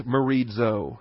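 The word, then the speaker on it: merizo (μερίζω): Your word there is merizo. M-E-R-I-Z-O. Accent on the iota, accent on the I.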